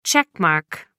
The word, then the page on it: check mark: American English